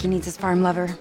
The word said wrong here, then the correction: forlover: It's pronounced Forlover. I don't have time to teach you Danish.